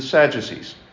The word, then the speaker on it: Sadducee: Now, when I was teaching what passed for religion at Mount St. Joe, my first year, just to be snarky, I would pronounce it the Italian way, so the Pharisees and the Sadducees. And so the kids started picking that up, and they were like, oh, so the Sadducees would say this, or the Sadducees. And it was funny, and then I realized, my God, they're going into the next grade, and they're going to still be saying this. So I'm like, no, it's pronounced Sadducee, Sadducee. I was only joking.